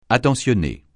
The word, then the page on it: attentionné: francais